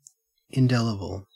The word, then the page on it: indelible: Australian English